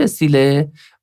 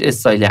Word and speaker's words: استیل: این واژه تلفظ فرانسویش استیله و تلفظ انگلیسیش استایله.